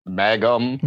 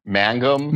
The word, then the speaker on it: Mangum: It's uh, it's it's not pronounced like Magna. It's man- Mangum.